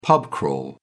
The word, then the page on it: pub crawl: British English